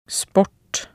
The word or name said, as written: sport